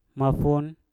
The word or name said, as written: mafun